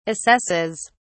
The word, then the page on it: assesses: British English